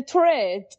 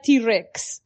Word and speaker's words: Tourette: Eh, que no sé exactamente si en español se dice así, eh, perdona mi, ignorancia, eh, pero en inglés se dice Tourette. pero yo con mi pronunciación de estar por casa me solté un día que el niño tenía T-Rex, como el dinosaurio.